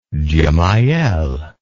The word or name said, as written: gemayel